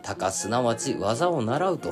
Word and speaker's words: 鷹乃学習: こちらね、まあ、漢字はまたタイトルの方に書いておくんですけれども、鷹の学習と書いて、鷹すなわち技を習うと読みます。